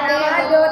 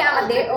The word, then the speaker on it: Amadeo: Kalau juga masih bilangnya ah, soto bukan Amado, tapi Amadeo.